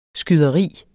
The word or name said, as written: skyderi